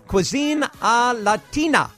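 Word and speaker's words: Cuisine à Latina: Did I pronounce that right? – You actually did. – Cuisine Latina.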